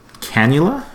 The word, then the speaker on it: cannula: Going back to factual errors, Dylan's nasal cannula, is that how you pronounce it? – Yeah.